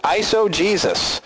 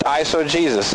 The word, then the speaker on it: eisegesis: And how to pronounce that is eisegesis. I know it doesn't look like it says Jesus, but the word is pronounced eisegesis.